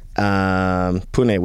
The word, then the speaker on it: Pune: Pune, which is how you pronounce it, by the way.